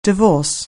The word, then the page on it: divorce: anglais